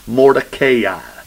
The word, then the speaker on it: Mordecai: That's the way you actually pronounce it. And when I actually learn that I can pronounce something right, I try to use it because I'm not very good at it. I used to say Mordecai. It ain't Mordecai, it's Mordecai. But you can say Mordecai if you want to be wrong.